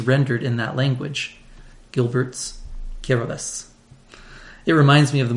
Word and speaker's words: Kiribati: And this pronunciation seems bizarre until you learn that in the Gilbertese language of Kiribati, TI is pronounced S.